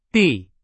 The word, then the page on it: 地: Chinese